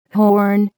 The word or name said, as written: horn